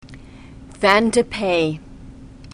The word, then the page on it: Vin de Pays: French